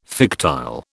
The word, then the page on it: fictile: British English